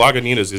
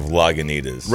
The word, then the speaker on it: Lagunitas: Actually, Lagunitas is mispronounced so much that they have to put the correction on each of their bottles.